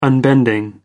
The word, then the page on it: unbending: American English